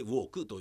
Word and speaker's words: WWUK: どういうことかというとこの韓国人 YouTuber ウォークという人が、まあ、若い人なんですけどねえー、僕から見たら若い人ウォークというのは WWUK と書いてウォークと読みます